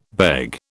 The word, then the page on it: beg: British English